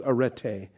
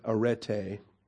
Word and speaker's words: arete: And this is arete. This is the feminine noun of arete. A-R-E-T-E. You got the short E followed by the long E. Alpha, Rho, Epsilon, Tau, Eta. Arete.